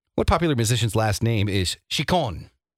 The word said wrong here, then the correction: Ciccone: See, I pronounced it wrong, so you got the spelling.